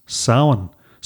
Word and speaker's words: Samhain: but we know as Samhain. So that's spelled S-A-M-H. A-I-N in Irish,